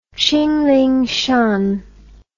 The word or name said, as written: qinlingshan